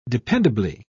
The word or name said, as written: dependably